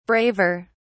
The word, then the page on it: braver: British English